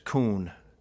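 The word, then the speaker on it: kun (כון): And these are just a lot of the uses of this word, which is pronounced kun in the Hebrew.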